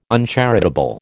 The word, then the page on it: uncharitable: American English